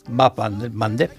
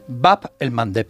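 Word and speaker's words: Bab el-Mandeb: Bap el Mandeb. – Bap el Mandeb, esa me la voy a apuntar. – Cuesta un poquito pronunciarlo, Bap por eso Mandeb. En Occidente se dice el Mandeb.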